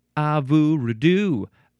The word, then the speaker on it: Avurudu: Avurudu, Avurudu, Avurudu, doobadoo-doo-boo. This is spelled A-V-U-R-U-D-U.